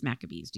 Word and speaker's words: Maccabees: And uh, I didn't know if I said that right, but I think it's Maccabees. Do you? – Uh, yeah, it looks like Maccabees is pretty, pretty right to me.